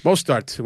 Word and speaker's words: Mostert: Mostert. – Sounds about right. – Mostert, right? Yeah. Like mustard, like mustard. Whatever it is, I don't know. – Mostert, whatever.